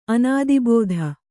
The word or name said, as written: ಅನುದ್ಭೂತ